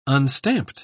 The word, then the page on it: unstamped: British English